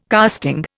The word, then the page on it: casting: Indian